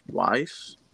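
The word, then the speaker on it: Weisz: I think it's Weiss. Uh, that's how you pronounce it.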